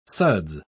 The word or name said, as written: suds